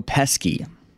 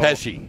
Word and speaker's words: Pesci: De Niro, Al Pacino, or Joe Pesci? – Oh. – Did I say it right? – Pesci. – Pesci. There we go.